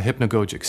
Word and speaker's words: hypnagogic: That's spelled H-Y-P-N-A-G-O-G-I-C, hypnagogic.